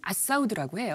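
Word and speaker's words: Al Saud: 근데 알사우드는 아랍식 발음으로는 아사우드라고 해요.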